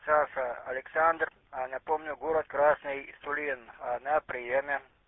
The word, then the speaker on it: Сулин: Я сейчас один раз вам по буквам передам город, вот, я смотрю, вы неправильно произносите его. Сергей, Ульяна, Леонид, Иван, Николай, Сулин.